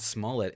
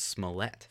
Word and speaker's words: Smollett: It's not Smollett. It's Smollett. – Smollett. – John Oliver did a segment on him. Way to steal our ideas, John Oliver. And it's pronounced Smollett.